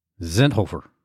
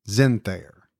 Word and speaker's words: Zenthofer: Now, she sent me the pronunciation. I believe it's Allison Zenthayer. – Really? – But I would never have gotten that from the spelling. I would have said it very much the way you did.